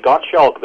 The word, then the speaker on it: Gottschalk: and that's where Gottschalk comes in. – Now, is he also known as Gottschalk of Orbai? Is this the same figure from the 9th century? Yes. I say Gottschalk.